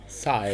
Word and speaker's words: Scythe: War richtig mit Scythe. Scythe. – Hm, Scythe. Klingt so wie Siegfried und Roy.